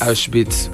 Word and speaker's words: Auschwitz: Auschwitz. – Ok, ajá. ¿Se escribe? – No te voy a decir cómo. Porque es como alemán el nombre. Se escribe A-U-S-C-H-W-I-T-Z, pero la W allá se pronuncia como B.